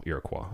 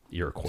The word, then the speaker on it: Iroquois: No, Iroquois is the correct pronunciation. Iroquois. – Tomato, tomato. – Mm-mm. Sorry, I'm going to have to put my foot down. Iroquois.